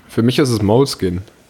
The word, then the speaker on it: Moleskine: Moleskine. – Also ich würde. Du hast aber doch die Betonung bisschen hinten auf dem Kien, ne? – Ja, Moleskine.